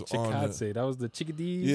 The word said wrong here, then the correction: Chikadze: Yeah, I never know how to pronounce it, and then Chikadze. I heard the dude say it. I'm like, oh, okay, that's how you do. – Chikadze.